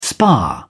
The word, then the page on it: spar: British English